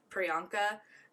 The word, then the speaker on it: Priyanka: I'm sorry that I'm probably not going to pronounce her name right, but it's like Priyanka. – Sounds right.